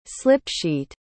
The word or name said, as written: slip-sheet